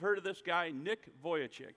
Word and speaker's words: Vujicic: That's how you say that. I mean, you cannot say this phonetically. I had to practice this for like a half an hour to get it. But it is Vujicic.